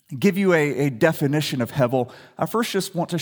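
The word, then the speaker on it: hevel (הֶבֶל): Say that word with me, hevel. Some would pronounce it hebel or hevel. We're going to use hevel.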